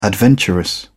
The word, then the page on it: adventurous: British English